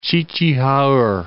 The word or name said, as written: Ch'i-ch'i-ha-erh